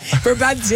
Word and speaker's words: Brabantia: I'm talking Brabantia, babes. Is that how you say it? – Brabantia, yeah.